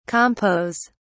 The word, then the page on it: compos: British English